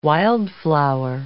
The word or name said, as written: wildflower